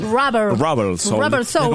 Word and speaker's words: Rubber Soul: Hablamos de, ¿está bien pronunciado? Robert Soul. – Rubber Soul. – Como alma de goma, eso, ¿no?